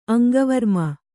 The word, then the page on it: ಅಂಕವಿರಾಮ: aŋkavirāma